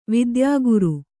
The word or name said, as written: ವೈದ್ಯಕಾರ